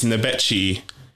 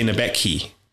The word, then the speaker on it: Nebechi: The surname is funny because it's like, it's Nebecci here, but Italians say Nebecchi because of the pronunciation of the language.